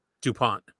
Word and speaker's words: DuPont: He goes, "You guys, how did you pronounce his last name? – DuPont."